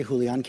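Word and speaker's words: Julián: Julian Castro. – And of course, I've been saying his name wrong all along. I gave it a hard J.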